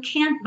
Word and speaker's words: Can't: Nessa canção, a gente tem várias vezes a letra T e a letra D, que são consoantes oclusivas, a gente consegue vê-las pintadinhas de vermelho, precedidas de letra N. Can't, diamond, friend. E quando isso acontece, é possível que essas consoantes oclusivas não sejam pronunciadas, praticamente não apareçam.